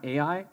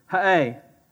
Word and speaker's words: Ha'ai: We've always called this town Ai, but I found the actual pronunciation of this name, and it's hilarious. So they are so country that it's in their name. It's Ha'ai. And you could probably go, hey, you know, you're saying it right if you say it that way, just so you know. If you look at the Hebrew, the way it's pronounced, it's H-A-A-Y.